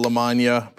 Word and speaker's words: LaMagna: Ro Khanna, Randy Bryce, otherwise known as Iron Stash, Abdul El Said, Del LaMagna, pronounced like lasagna.